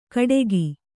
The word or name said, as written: ಕಡೆಗಿ